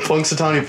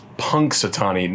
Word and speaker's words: Punxsutawney: I remember I have done like the least amount of research on Punxsutawney Phil. I've done enough research to know that when you say his name, it's Punxsutawney, not Punxsutawney.